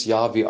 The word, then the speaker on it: Yahweh: Wenn wir ins Alte Testament zurückgreifen, dann hat Gott sich offenbart als ähm, Jahwe, beziehungsweise wir sprechen das Yahweh aus. Wie es wirklich ausgesprochen worden ist, weiß man nicht hundertprozentig, weil die hebräische Sprache ähm, keine keine Vokale hat und deswegen wir nicht wissen, wie es genau ausgesprochen worden ist, aber die wahrscheinlichste Form ist Yahweh und Yahweh könnte der Name Gottes sein.